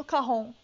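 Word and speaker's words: cajón: Sabe por que, que é carrom? Porque é uma palavra em espanhol. E em espanhol, o J tem som de R.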